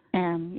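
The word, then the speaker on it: Anne: So it's Ann. You don't pronounce the E at the end, just Ann. – Ann, yes, I usually pronounce just Ann.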